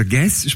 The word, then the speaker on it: Guez: Der schreibt sich G-U-E-Z, genau. Also Guess spricht man das aus, das ist ein Franzose, kann aber sehr gut Deutsch, hat lange in Deutschland studiert.